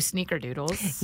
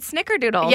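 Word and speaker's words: snickerdoodles: I've always said snicker doodles. – Yeah, it is snicker, but we've always said sneaker doodles.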